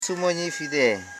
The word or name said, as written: su.mo.nyi